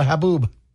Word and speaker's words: haboob: It's called a Haboob. – I'm sorry, what? – The storm is called a Haboob, H-A-B-O-O-B.